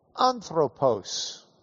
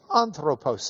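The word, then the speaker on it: Anthropos (ἄνθρωπος): This is a Greek word, A-N-T-H-R, long O, P-O-S. In English letters, that would be an A-N-T-H-R, long O, P-O-S. Anthropos. Anthropos. Say it with me. Anthropos.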